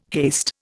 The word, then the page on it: haste: Indian